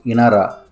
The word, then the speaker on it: Inara: The word of the week is a beautiful Arabic word pronounced Inara.